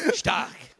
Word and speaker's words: stark: How do you say it? Stark. Stark.